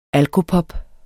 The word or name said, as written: alkopop